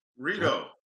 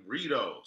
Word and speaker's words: Reedo: Redo, whatever your name is. – No, this show is going. Rido. – What does it mean? – That's my name, Rodney. Rido. Say my name.